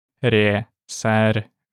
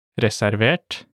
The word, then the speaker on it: reservert: Let's break them down by syllable. Reservert, reservert, and ureservert, ureservert. Let's now imagine you want to buy a reserved seat ticket.